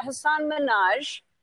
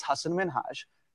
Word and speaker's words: Hasan Minhaj: But the real way you pronounce it, and this is a big deal because my parents are here, it's Hasan Minhaj. And people always mispronounce it.